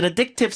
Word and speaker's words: addictive: It's an addictive sport. 발음 다시요. Addictive. – Addictive. – 어가 아니라 의에 가깝죠? Addictive. – Addictive. – 네, 강세가 없는 음절은 의로 약화시켜서 읽습니다.